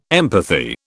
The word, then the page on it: empathy: British English